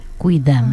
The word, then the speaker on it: quidam: Quidam, ¿no? Claro. En español se escribe igual con Q-U-I, pero se pronuncia, como pronunciamos en español, Q-U-I, es decir, quidam.